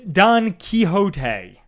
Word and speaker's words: Quixote: I yes, Don Quixote.